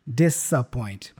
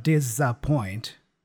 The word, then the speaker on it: disappoint: Notice that the S there is also pronounced as an S sound, disappoint, instead of disappoint with a mm-hmm. Z sound.